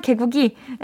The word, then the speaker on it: 개구기: 이러면서 엄청 신기해 하시다가 결국 개구리로 입을 벌려서 고정시켰어요. 대단하신데요? 옛날에 라미네이트 하다가 잠든 적이 있는데, 아, 개구기를 개구리라고 읽었네요. 죄송해요. 우리 볼륨 가축도 다 아시잖아요. 개구기, 에, 개구기. 그거 이 벌리게 하는 거.